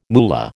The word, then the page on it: moolah: American English